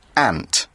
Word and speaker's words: aunt: In the US, it is kind of pronounced more like ant, like ma'i, right? – So ma'i, yeah. – Yeah. – The fa'in. – Yeah. Mm-hmm. In the UK, we say aunt.